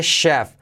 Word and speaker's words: chef: Mirad cómo se pronuncia, letra clave SH, chef.